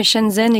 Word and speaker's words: Shenzhen: Je vais très mal prononcer, mais Shenzhen et Xi'an, c'est ça? Mais vous avez bien prononcé.